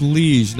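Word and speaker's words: Liege Lord: It's pronounced liege, not liege. Liege.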